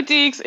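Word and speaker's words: Deacs: Okay, so you are a Wake Forest Demon Deeks. Is that how you say it? Deacon? – Yeah, go Deeks. – Go Deeks.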